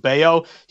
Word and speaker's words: Bello: his last name is spelled Bello, but it's actually pronounced Bayo.